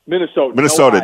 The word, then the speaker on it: Minnesotan: But what a night for your fellow Minnesotian. That's how you say it, right? Minnesotian? Am I right? – Minnesotan, no, yes, there you go.